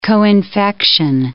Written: coinfection

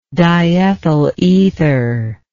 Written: diethyl ether

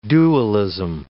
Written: dualism

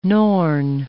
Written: Norn

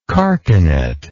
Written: carcanetted

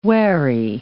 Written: wary

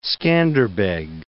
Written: Scanderbeg

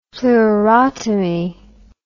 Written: pleurotomy

